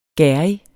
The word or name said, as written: gerrig